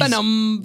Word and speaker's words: Venom: The way that he says Venom in it is like he's Venom. – Yeah, he's got like one syllable, but Venom is two.